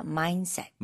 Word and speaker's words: mindset: 중간에 mind, D가 있잖아요. – 네. – 근데 여기서는 D를 많이 발음 안 해도 돼요. – 음흠. – 그래서 mindset.